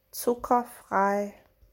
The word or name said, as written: Zuckerfrei